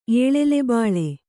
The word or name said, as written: ಏಳೆಲೆಬಾಳೆ